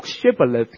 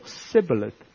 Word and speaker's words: shibboleth: 原来以法莲人读 shibboleth，他们是读 sibboleth，露出了马脚，原来读音不正可以累到你连命都没埋，就是因为这样露出身份，露出马脚。很有趣，因为这个故事 shibboleth